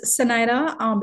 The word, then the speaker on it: Zenaida: Actually, it's Zenaida because it's pronounced Spanish. – Okay. – My dad is Spanish, so it's pronounced Spanish, but it's spelled with a Z, but it's like an S, you know? – I like it.